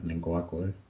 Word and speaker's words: Ngakoue: Um, um, Yannick Ngoye. I can't pronounce his last name. – Ngakoue. yeah, Ngakoue.